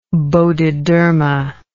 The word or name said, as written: Bodhidharma